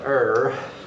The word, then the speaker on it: err: If she could err, I believe that's the correct way to pronounce it, believe it or not, err, and thereby lead the faithful astray, it would mean that Satan has triumphed over Christ.